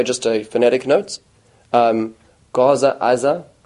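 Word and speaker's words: Aza (עזה): And going a little further back, so it's the Gaza, which is actually why, translated wise, it's Gaza, even though in Israel it's Aza.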